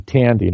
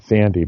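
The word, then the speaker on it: Thandie: and it appears the name is actually Tandy, not Sandy, because I heard Joy Nolan say her name is Tandy.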